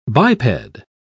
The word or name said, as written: biped